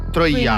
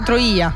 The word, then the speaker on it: Troia: Hanno cambiato l'accento sulla I. – E giusto. Troia. – Quindi, Troia, sì, esattamente.